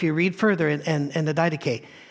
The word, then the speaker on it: Didache: One of the earliest writings about the Christian life and the attitude of Christians toward life is the Didache, or it's D-I-D-A-C-H-E. And Didache is how most people pronounce it.